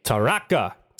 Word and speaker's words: Taraka: So I'm going to play a song by Gordo, and it's called Taraka. Don't even know if that's how it's pronounced, but it's spelled T-A-R-A-K-A. – Taraka. – Taraka. – Sounds right to me.